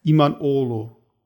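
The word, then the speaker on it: İmamoğlu: Genau jetzt wie der neue Oberbürgermeister von Istanbul heißt Iman Olu. Und das schreibt man Iman Oglu.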